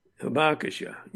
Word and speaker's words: Hibakusha: Hibakusha, yeah.